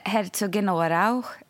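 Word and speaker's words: Herzogenaurach: Men det heter Herzogenaurau, fast man ska ha någon liten sån här bra på slutet som jag inte riktigt får till.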